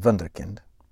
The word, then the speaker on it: Wunderkind: That's right, I said Wunderkind. That's the correct pronunciation, a German word.